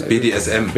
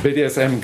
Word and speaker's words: BDSM: Und dann in Wirklichkeit ist es aber dann eigentlich eine BDMS, ich kann es nicht mal aussprechen, ups. – BDSM. – BDSM, genau.